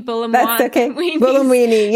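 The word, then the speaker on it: Buolamwini: Sorry, I always mispronounce her name. – Bulamwini.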